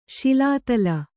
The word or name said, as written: ಶಿಲಾತಲ